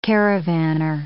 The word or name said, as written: caravanner